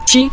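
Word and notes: ji: tone 5

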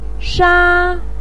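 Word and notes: sha: tone 1

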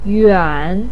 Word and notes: yuan: 3

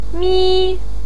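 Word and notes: mi: tone 1

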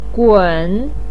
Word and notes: gun3: pinyin

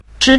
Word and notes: zhi: zhi5

